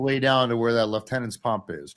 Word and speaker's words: Lieutenant's Pump: You said it correctly, sir, but you're military, so you know, Lieutenant's Pump. – Correct. – You know, that's how you say it.